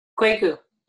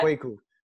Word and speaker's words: Kwaku: So I guess my question, if I had one, would be, and I'm sorry that, is his name pronounced Kwaku? – Kwaku, yeah. – Kwaku.